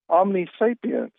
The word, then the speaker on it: omnisapience: Omnisapience.